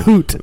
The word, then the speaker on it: Bhoot: B-H-O-O-T. Boot. Okay. – Oh, all right. – You're right. – Spell it again. – B-H. – Oh. – Boot.